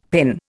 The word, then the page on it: PIN: Indian